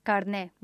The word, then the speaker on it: carnet: Carnet with an E, and that's because it's a word that comes from French, carnet. And carne is how it's pronounced in French also, carne.